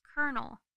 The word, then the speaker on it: colonel: I don't mind the way it's spelled. I mind the fact that it's pronounced colonel.